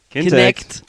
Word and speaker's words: Kinect: Unter anderem natürlich das ehemalige Projekt Natal, was jetzt Kinetic heißt. – Falsch, nee. du hast das schon falsch nee. gesagt. – No. Das habe ich falsch gesagt. Kintech. Kintech. – Kinect. Kinect, verdammt. Kinect.